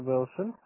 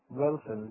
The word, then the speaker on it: Wilson: My name is Peter Wilson. And remember these things, that whatever happens today. Okay? – Peter? – Yes. Wilson. – Peter. How do you spell it? – Wilson. W-I-L-S-O-N.